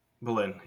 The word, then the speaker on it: Boleyn: Boleyn, am I saying that right? Boleyn, right? – Anne Boleyn. – Boleyn, yeah, I know.